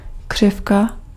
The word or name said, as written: curve